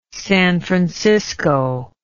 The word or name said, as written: San Francisco